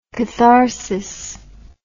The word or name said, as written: katharsis